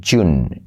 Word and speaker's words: June: No se dice June, sino June. June. June, ¿vale? Fíjate que es como, bueno, como en January, ya lo hemos visto.